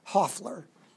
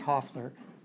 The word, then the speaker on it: Hoffler: It's Hoffler, spelled crazy, but it's pronounced Hoffler.